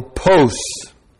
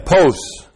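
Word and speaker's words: post (πῶς): Now that word why is the interrogative particle post. P long O S, post.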